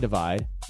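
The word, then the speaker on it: ÷: They're pronounced plus, equals, multiply, and divide, but spelled with just the symbols, and Ed revealed his initial inspiration came from having very big ambitions before his career was really going anywhere.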